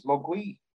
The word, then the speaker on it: Mogwai: In Cantonese, Mogwai means devil, demon, or gremlin. Oh. Mandarin pronunciation is Mogui.